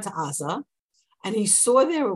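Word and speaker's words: Aza (עזה): And just for those of you who don't get this, in English we call it Gaza. The Hebrew is Aza. There are the pronunciation of that ayin in many dialects is a gimel, but it's really Gaza, the same old place where we have lots of trouble from them.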